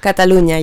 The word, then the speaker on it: Catalonia: Is that, did I pronounce it right? – Catalonia, yeah.